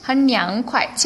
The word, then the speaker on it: 很凉快: 요거는 성조를 좀 주의하실 게 快가 경성이에요. 우리가 빠르다 형용사일 때는 快가 사성이었거든요. 快, 很快. 그런데 이때는 很凉快, 칭성으로 발음하시면 되겠습니다.